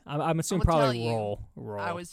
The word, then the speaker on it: Royal: It's pronounced by older Blount Countyans, Royal, as if the Y is silent.